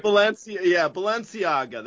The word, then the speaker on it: Balenciaga: So the company, I don't know how you pronounce it, Balenciaga, is that – are you familiar? You like high-end stuff, right? – Balenciaga, that's correct.